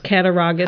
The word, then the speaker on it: Cattaraugus: So again, I believe it's pronounced Cattaraugus. – How's it spelled? – C-A-T-T. A-R-A-U-G-U-S. – Yeah, I would Cattaraugus. Say Cattaraugus.